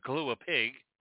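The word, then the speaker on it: kalua pig: Kahlua Big, a Kahlua Pig, let's say it right.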